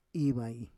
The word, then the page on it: ivai: Roon